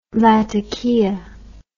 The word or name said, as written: lattakia